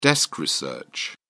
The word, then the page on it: desk research: British English